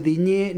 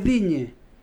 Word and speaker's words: Digne: no se dice Digné ni Olicé. – ¿Ah, no? – No, porque no tiene acento en la E. Las E finales en francés, si no tienen acento, no se pronuncian. Es Digne.